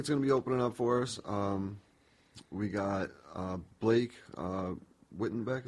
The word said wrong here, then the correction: Whitbeck: Is that how you pronounce it? – Whitbeck, yep.